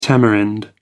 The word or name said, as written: tamarind